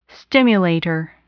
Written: stimulator

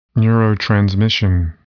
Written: neurotransmission

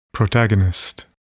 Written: protagonist

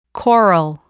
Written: coral

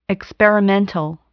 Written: experimental

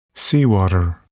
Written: seawater

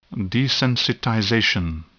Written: desensitization

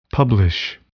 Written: publish

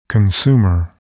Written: consumer

